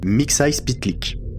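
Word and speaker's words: Mxyzptlk: mix pit Pitlick Bravo.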